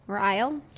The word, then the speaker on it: Ryle: How would you pronounce the name R-Y-L-E? – Ryle? – Yeah. – Riley? – No, it's not Riley. It's Ryle.